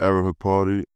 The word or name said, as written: ariel HUKPORTI